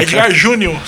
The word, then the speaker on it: Edgar: É Edgar Júnior, sem e é, R. É, e o D não é mudo, é Edgar Júnior.